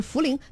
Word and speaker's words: 茯苓: 然后呢，这萧孟子还说一诺发音是茯苓，对，就是茯苓。